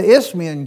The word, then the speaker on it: Isthmian: In those Isthmian games, and it sounds like I've got a lisp, but that's the way that is pronounced, the Isthmian games, they were...